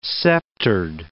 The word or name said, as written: sceptered